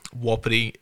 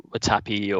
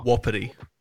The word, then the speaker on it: Wapiti: Although I can't pronounce it. Watapi or Wapiti. – Yeah, that, so that's that just one. the it's it's something that frustrates me a lot. It's the Native American word for elk, um, ah, okay.